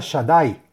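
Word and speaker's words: Shaddai (שדי): Trae un nombre sagrado para meditar y son las letras Shin Dalet Yud, que se pronuncia Shaddai y que es el nombre de Dios en las Firayesod.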